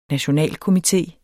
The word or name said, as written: NATIONALKOMITE